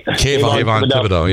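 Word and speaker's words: Kayvon Thibodeaux: Considering we lost half our secondary in the free agency, we have a solid linebacking court, good Kayvon Thibodeau, I can't even say his name. – Kayvon Thibodeau, yeah.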